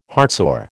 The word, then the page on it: heartsore: American English